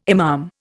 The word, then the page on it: imam: Indian